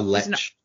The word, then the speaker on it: lich: But Vecna in Dungeons and Dragons is He is a how do you pronounce this? It's L-I-C-H. – A lich.